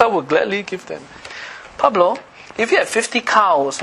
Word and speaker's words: Pablo: P-A-B-L-O, how do you pronounce that? – Pablo.